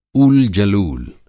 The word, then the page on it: ऊल-जलूल: Hindi